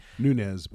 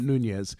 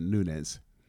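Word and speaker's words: Nunes: Nunez, by the way. Not Nunez. – It's not? – You would think that it would be pronounced that, and he may actually have Spanish ancestors, but if he does, he's ignoring them. He pronounces it Nunez.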